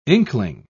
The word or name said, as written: inkling